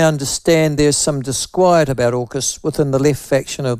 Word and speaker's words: AUKUS: We pronounce it AUKUS.